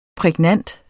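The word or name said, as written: PRÆGNANTE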